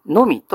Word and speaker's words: 耳: さらに一番下の耳というのはのみと読むんです。